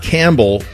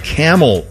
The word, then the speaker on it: Campbell: Not Campbell, but Camel, Ohio. So Campbell is pronounced Camel.